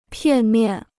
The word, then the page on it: 片面: piàn miàn